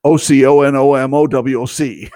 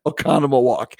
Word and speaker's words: Oconomowoc: And if you see Oconomowoc, you'll know that that's not the way you pronounce it. Oconomowoc. Oconomowoc.